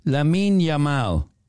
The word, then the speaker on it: Lamine Yamal: Lamine Yamal.